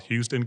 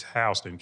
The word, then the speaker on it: Houston: things like the state of Alabama has a county called Houston County. It's spelled the same way in Georgia, but it's Houston County.